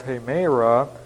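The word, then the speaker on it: hemera (ἡμέρα): And then, of course, we have the phrase here in this context, 1 Thessalonians 5, we have hemera, and that's a long E, kurieu, M-E-R-A, and then K-U-R-I-O-U, kurios, the word for Lord.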